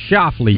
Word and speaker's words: Schauffele: Schauffele? – Oh, Xander Schauffele.